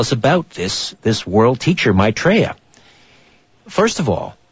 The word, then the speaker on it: Maitreya: Maitreya.